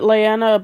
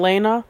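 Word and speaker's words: Laena: Okay, I've always pronounced it Leanna, but I'm hearing Lena. So. – Yeah, I don't know. I, I thought it was pronounced Lena too.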